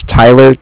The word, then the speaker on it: Tyler: Yeah, just put down that my name was Tyler, T-Y-L-E-R, and that I'm calling with Cardiff. It's spelled C-A-R-D-I-F-F.